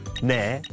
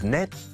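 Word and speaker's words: 네: When counting like this, the last letters aren't pronounced. It's han instead of hana, tu instead of tu, se instead of set, ne instead of net, and sumu instead of sumul.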